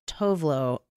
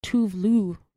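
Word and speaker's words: Tove Lo: So I actually have had Tovlo on repeat a little bit. – Someone told me that it's pronounced Tuvlu because she's Swedish, right? – Tuvlu.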